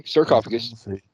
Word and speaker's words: sarcophagus: How do you say that place? That thing? That box? – Yeah, sarcophagus. Or casket.